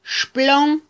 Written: xplon

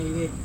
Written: ai re

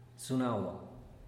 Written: zunagua

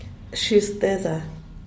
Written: shishteza

